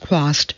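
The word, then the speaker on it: Chwast: Trost, pronounced Quast, Heller informs us the name is Polish for weed.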